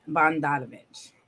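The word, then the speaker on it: Bogdanović: Bogdanovich. However you say his name. – You said it right? – Yeah.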